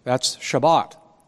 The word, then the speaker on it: Shabbat: That's Shabbat, as they pronounce it.